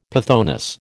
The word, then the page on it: pythoness: American English